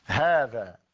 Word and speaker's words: هذا: من الحروف التي تنطق ولا تكتب منها الألف في كلمة هذا. – هذا إذا كتبنا كلمة هذا بالرسم العروضي هكذا، هذا، هذا هو الرسم العروضي.